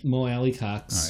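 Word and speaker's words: Mo Alie-Cox: And Mo Alley Cox. You say his name. – All right. – That's a fun name to say. – Mo Alley Cox.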